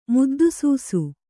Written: ಮುದ್ದುಸೂಸು

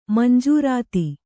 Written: ಮಂಜೂರಾತಿ

